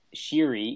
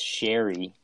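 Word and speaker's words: Sheary: Connor Sherry. He wanted his name to be pronounced Sherry. – Yeah, that's how it's like Sherry instead of Sherry.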